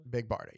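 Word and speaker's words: Big Barda: Big Barta, yep.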